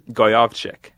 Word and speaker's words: Gojowczyk: So last name Gojowczyk. – Nailed it. – Yeah. – How do you pronounce that again? – Goyovchik. – Gojowczyk.